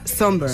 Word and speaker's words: Sombr: Pues mira, yo busqué cómo se pronunciaba por si acaso, pero efectivamente se pronuncia Somber. – Somber, sí. – Y se escribe Somber. Sí, sí, sí. Exacto. – Y se escribe S-O-M-B-R, por si la gente lo quiere buscar.